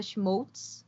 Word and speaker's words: Schmolz: Então, vocês podem me encontrar tanto no Instagram quanto no Twitter como arroba Fernanda Schmoltz. O meu sobrenome se escreve S-C-H-M-O-L-Z.